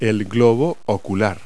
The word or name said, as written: eyeball